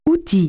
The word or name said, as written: tool